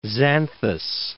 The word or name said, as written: Xanthus